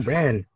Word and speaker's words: Bren: You keep saying Bren. – Bren. – It's not an A, bro. – It rhymes with Ben, i.e. the character he played in Scrubs.